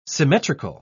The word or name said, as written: symmetrical